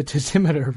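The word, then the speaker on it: tasimeter: And maybe people haven't heard about his tessimeter. Is that how you say it? – Yes, the tessimeter, right.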